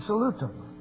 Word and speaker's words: salut: I looked at the Latin Bible in Salutum, French Bible, La Salute, or Salut. Don't pronounce the T.